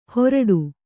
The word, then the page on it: ಹೋರಾಡು: Kannada